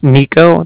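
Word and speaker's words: Niko: My name is Nico. That's N-I-K-O.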